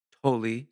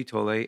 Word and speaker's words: Tolle: And so in closing, we'll leave you with a quote by one of my favorite authors, Eckhart Tolle, or Tolle. Some people pronounce his name Tolle.